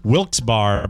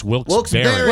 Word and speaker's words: Wilkes-Barre: I've said Wilkes-Barre, Pennsylvania for many, many moons, and it's Wilkes-Barre, B-E-R-R-E. – They make a great pie. – Yeah, this, no why. – The second half is spelled B-A-R-R-E, so a lot of people say Wilkes-Barre, but it's Wilkes-Barre.